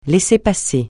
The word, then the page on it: laissez-passer: francais